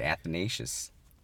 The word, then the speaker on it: Athanasius: Saint Athanasius, and correct me if I'm pronouncing it incorrectly. – You got it. You nailed it, Athanasius.